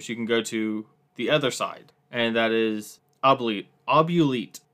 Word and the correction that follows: Oubliette: I'm saying, I hope I say that right. Oubliette. Oubliette. Thank you.